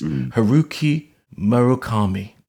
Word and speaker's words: Haruki Murakami: So we're going to start with your favorite book, which is, you've got The Wind Up Bird Chronicles by, I've been pronouncing this, Haruki Murakami. – Yeah, that's very good.